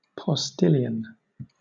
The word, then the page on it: postilion: British English